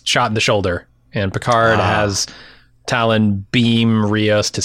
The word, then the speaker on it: Picard: He has reestablished Jean-Luc Picard as the way to pronounce his Frankenstein, not Frankenstein.